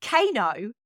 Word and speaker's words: KEiiNO: And Kano sounds a little bit like Keanu. And it's also spelled a little bit like Keanu as well. So it's spelled K-E-I-I-N-O.